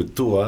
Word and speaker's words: Toa: a un double sens parce que Toa, euh, c'est la phonétique du mot table en thaïlandais. – Donc ça se prononce ah. To.